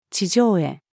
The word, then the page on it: 地上絵: female